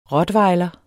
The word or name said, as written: rottweiler